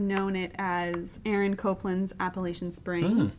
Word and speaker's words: Appalachian: That is how you pronounce it. – There are some people who might say that you pronounce it Appalachian Spring. – No. – I feel like that's a regional thing. – You're backing me up. I grew up at the base of the Blue Ridge Mountains in Appalachia, and you're pronouncing it correctly.